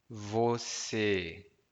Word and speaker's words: você: Once again, você. Two syllables. Você.